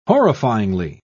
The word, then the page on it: horrifyingly: British English